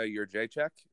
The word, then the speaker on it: Jędrzejczyk: Zhang Weili and Joanna Jędrzejczyk. I finally said it right.